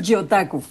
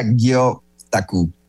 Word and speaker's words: gyotaku: Entonces, a ver, cuéntanos, ¿qué es esto del yotaku? – Pues mira, en realidad se pronuncia gyotaku. Gyo significa pez y taku significa frotar.